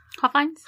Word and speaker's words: Hofheinz: Elvis performed in Houston June 4th, 1975 at the Hofheisen? How do you say that? – Hofheinz. – Hofheinz? – Mm-hmm. – See, I was going back to that German thing.